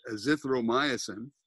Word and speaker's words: azithromycin: Is hydroxychloroquine, and he's got that in combination with, uh, uh, and I can never pronounce this, but azithromycin, right? A Z I T H R O M Y C I N, azithromycin is this romyosin.